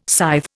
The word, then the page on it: scythe: Indian